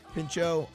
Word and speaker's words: Pinchot: Pinchot.